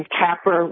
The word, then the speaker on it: Kaepernick: I wanted to talk about the flag and Colin Kaepernick. Well, the last part rhymes with a man's genitals.